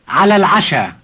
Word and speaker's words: على العشاء: على العشاء. – Notice that we drop the initial sound e in il when we pronounce for and dinner together.